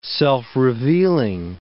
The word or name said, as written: self-revealing